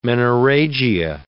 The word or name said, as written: menorrhagia